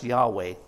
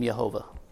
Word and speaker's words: Yahweh: In Hebrew, it's pronounced Yahweh. After the King James Version came out, it became Jehovah. Either one's okay.